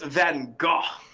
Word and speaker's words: Van Gogh: She was like, hey, Vincent Van Gogh didn't draw this. – Exactly. It's pronounced Van Gogh.